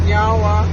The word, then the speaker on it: Nyawa: So in our language, we say Nyawa, which means thank you. – Nyawa. – Nyawa. – I said it right? – Yeah, you did.